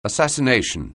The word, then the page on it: assassination: anglais